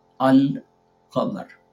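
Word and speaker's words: Al-Qamar (القمر): If you have a moon letter and you add Al before it, you'll pronounce both fully, Al-Qamar.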